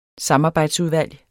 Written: samarbejdsudvalg

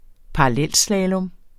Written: parallelslalom

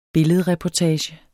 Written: billedreportage

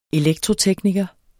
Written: elektrotekniker

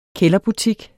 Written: kælderbutik

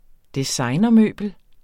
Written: designermøbel

